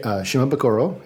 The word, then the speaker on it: Shimabukuro: Shimabukuro, yep.